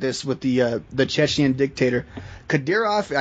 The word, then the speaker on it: Ramzan Kadyrov: It's like Ramzan Kadyrov, I believe. – That's just right.